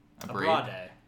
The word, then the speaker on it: Abrade: Abrade. Am I saying that correctly? I think I am. – Abrade. – Abrade!